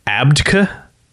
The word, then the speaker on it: Abcde: The name it's spelt A B C D E, um, but it's they they're pronounced it like Abdka.